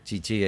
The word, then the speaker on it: Chichi: A lo mejor es chichi, es cierto, es con acento en la I. Estoy viendo acento en la última I.